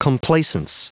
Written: complaisance

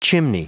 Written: chimney

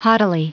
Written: haughtily

